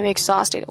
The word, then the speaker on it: exhausted: 哎，I am exhausted，我精疲力尽了。哎，更严重一点，可以说精尽人亡了啊。 Exhausted，注意一下这个 x 的读音啊，在这个后面是 z 啊，exhausted，not exhausted，exhausted。